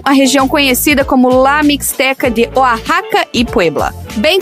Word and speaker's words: Mixteca: Pois é, e se escreve Misteca com X, Mixteca, que deve ter alguma coisa a ver com tipo México e Azteca.